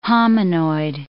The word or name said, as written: hominoid